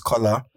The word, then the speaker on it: Kola: Like, now, if I introduce myself and I say my name is Kola, and someone calls me Kola, it goes over your head.